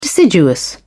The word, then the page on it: deciduous: British English